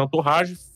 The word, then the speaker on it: Entourage: Como é que se escreve Antorrage? É Entourage, tá? Então, pronuncia-se Antorrage, fama e amizade.